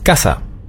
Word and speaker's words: Casa: Ja, das S, das weiche S, das gibt's im Spanischen nämlich nicht. Sie müssen immer scharf das S immer scharf aussprechen. Zum Beispiel Casa.